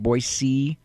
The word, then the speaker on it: Boise: Notice how I pronounce that, Boise. I'm not a geographic illiterate like Jack Williams thinks. I don't say Boise, Boise.